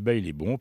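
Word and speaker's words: Baile Bom: Alors, Bail et Bon, ça s'écrit B-A-I-L-E, plus loin B-O-M. Mais ça se dit « bail est bon » parce que là-bas, c'est au Brésil.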